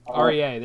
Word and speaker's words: Aurier: How do you pronounce that? I always butcher this. Was it Aurier or Art? Yeah, he was for Tottenham. Aria, there you go.